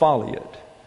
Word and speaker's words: foliot: And what happens is when this comes down, it causes the foliot, that says F-O-L-I-O-T, pronounced foliot. How do I know that? It's not in the dictionaries. So I wrote Merriam-Webster and asked them. And they wrote back and said foliot.